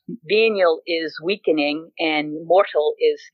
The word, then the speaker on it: venial: But the Catholics separate sins as mortal and venial. – Mm-hmm. – Saying that right. Is it venial? – Venial.